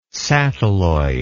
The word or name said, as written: satelloid